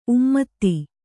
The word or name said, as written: ಉಮ್ಮದ